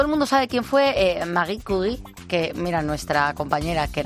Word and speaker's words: Marie Curie: A ver, sería Marie Curie.